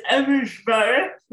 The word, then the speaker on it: Abujbarah: It's Abujbarah, but don't worry about it.